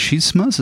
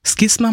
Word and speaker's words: Schisma: Jetzt sind das große Begriffe Schisma oder Skisma, man kann ja beides sogar sagen, und auch Exkommunikation.